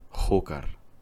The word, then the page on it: Júcar: Spanish